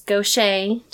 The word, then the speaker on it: Gaucher: and everyone pronounces incorrectly, and it's Gaucher disease, mm-hmm. but it's spelled Gaucher. It's, you know, mm-hmm. all these French people that they name things after, and no one can pronounce them.